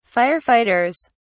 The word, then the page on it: firefighters: British English